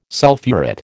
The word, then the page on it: sulphurate: American English